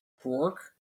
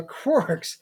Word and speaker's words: Krork: How did you say it again? – Quark? – Quarks. The Quarks. – K-R-O-R-K. – Quark. K-R-O-R-K. The Quarks and the Eldar breathing down their necks.